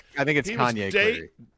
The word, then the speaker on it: Kanye Clary: Like, did I mispronounce his name? – Well, you did that too. You did that too, but that wasn't why we were laughing. So, I think it's Kanye Clary.